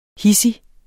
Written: hidsig

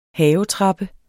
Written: havetrappe

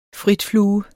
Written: frit

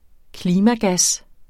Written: klimagas

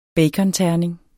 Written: baconterning

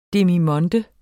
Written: demimonde

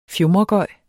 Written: fjumregøj

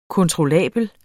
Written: kontrollabel

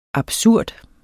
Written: absurd

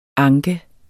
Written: anke

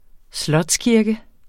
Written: slotskirke

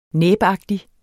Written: næbagtig

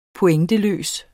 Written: pointer

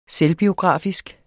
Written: selvbiografisk

